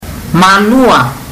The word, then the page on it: malua: fiji